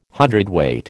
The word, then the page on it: hundredweight: American English